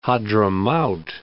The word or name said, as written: Hadhramaut